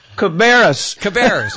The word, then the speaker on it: Cabarrus: Now, the 26% of the population was added in five counties, Johnston, Brunswick, and by the way, you're going to have to help me with the pronunciation of this one, Carrabas County? What? I wasn't even close, right? What is it? – You've screwed it up so bad I can't say it. Cabarrus, okay.